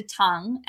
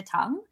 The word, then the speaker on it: Tung: It's called Dr. Tongue, and it's spelled T-U-N-G, Dr. Tongue.